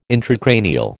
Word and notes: intracranial: American English

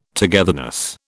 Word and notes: togetherness: British English

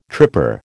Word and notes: tripper: American English

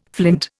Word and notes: flint: Indian

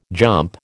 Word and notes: jump: American English

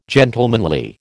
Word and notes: gentlemanly: American English

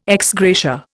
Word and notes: ex gratia: Indian

